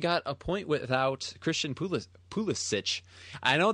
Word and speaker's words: Pulisic: It's Pulisic. That's how his grandpa pronounced it. It's how he should pronounce it.